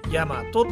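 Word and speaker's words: 8MATO: ヤマトって読みますまあワーキングプレイスっていう言葉が入ってることからわかると思いますがコワーキングスペースですでこのヤマト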